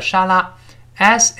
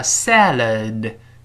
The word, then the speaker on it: salad: 沿着上牙根往后滑一下，反正 no，I mean 就是我是说 a salad 就是一个沙拉，s a l a d 读慢了叫 salad，注意前面发 a，然后后面发 a，所以读成我们大声读一下 salad，不要读沙拉的，不是沙拉，这这不能这么读，叫 salad，salad 这么一个声音，读快了之后，最后那个的。